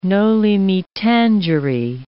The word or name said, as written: noli me tangere